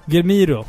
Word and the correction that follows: Guillermo del Toro: Är det rätt uttalat? Nej, Nej men... du bara hittar på! – Hur uttalas det då? Guillermo del Toro.